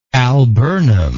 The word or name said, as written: alburnous